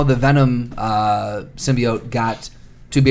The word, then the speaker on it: symbiote: Symbiote. I think it's symbiote. – Symbiote. – Right? – Thank you. – S-Y-M-E.